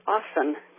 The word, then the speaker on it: often: And I don't know exactly when or why this came about, but the word often, like O-F-T-E-N, the T is silent.